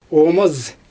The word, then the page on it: oomaz: Xyzyl